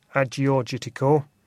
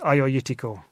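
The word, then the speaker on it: Agiorgitiko: And again, it's that G that becomes a Y. So if you look at it, it looks like Agiorgitico. But the correct pronunciation for those of us that have had it corrected by native speakers is Ioiutico.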